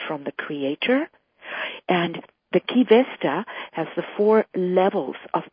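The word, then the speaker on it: Qi Vesta: Key Vesta is spelled Q-I and then V-E-S-T-A.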